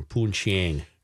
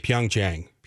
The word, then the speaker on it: Pyeongchang: I don't know what the arrangements are like in Pyeongchang. – Would not want to be there. – If I'm saying that correctly. – Not. Pyeongchang.